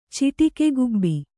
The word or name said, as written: ಚಿಟಿಕೆಗುಬ್ಬಿ